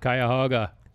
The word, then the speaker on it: Cuyahoga: Excellent pronunciation of Cuyahoga.